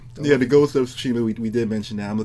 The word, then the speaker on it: Tsushima: First of all, it's called the Ghost of Tsushima. – Oh, there you go. – Okay. Oh, man. You and King of Hearts are so bad at pronouncing these Japanese names.